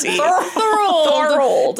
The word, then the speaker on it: Thorold: T-H-O-R-L-D. T-H-O-R-O-L-D. Thorold.